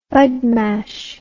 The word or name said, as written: badmash